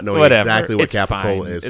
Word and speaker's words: capicola: It is a capicola. Is that how you say it? Capicola? – Capicola. – Capicola. C-A-P-I-C-O-L-A. So it's literally cap, I, and then cola.